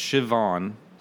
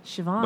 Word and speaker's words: Siobhan: It's like Gaelic or some. How do you pronounce it? – My Irish brethren. – Yeah, it's Siobhan. – Siobhan. – But it's spelled S-I-O-B-H-A-N.